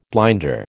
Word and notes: blinder: American English

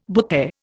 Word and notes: butte: Indian

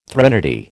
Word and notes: threnody: American English

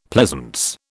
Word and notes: pleasance: British English

